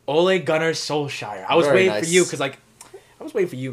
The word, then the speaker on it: Ole Gunnar Solskjaer: And we forgot to mention Ole Gunnar Solskjaer.